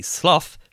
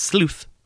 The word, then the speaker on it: sloth: And the collective noun for bears is a sloth, sometimes pronounced sleuth, of bears.